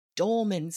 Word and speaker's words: dolman: Today we're talking about the dolman sleeve. That is spelled D-O-L-M-A-N, and it's pronounced dolman, not dolman. It's dolman.